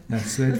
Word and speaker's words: Lassoued: Lassoued from Tunisia. – Good, you pronounce very well.